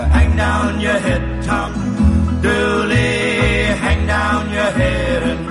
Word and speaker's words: Dula: The murder took place in 1866, and the gentleman's name was, surname was spelt D-U-L-A, but pronounced Dooley, and someone came along and wrote a song about it.